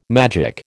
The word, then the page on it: magic: American English